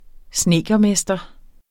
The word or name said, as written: snedkermester